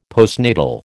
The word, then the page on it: postnatal: American English